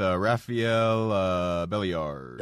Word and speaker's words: Rafael Belliard: I'm going to go with Rafael Belliard. – There you go, little Rafi. – You pronounced it right.